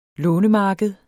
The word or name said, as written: lånemarked